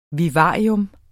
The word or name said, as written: vivarium